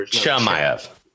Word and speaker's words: Chimaev: Khamaev. – No Chimaev.